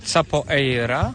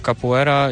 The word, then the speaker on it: capoeira: Ja myślę, że to może wynikać z pisowni, dlatego, że się pisze capoeira i wiele osób, którzy jakby nie znają do końca wymowy po portugalsku, po prostu czytają tak, jak się pisze, czyli capoeira, a mówi się capoeira.